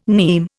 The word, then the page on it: nim: Indian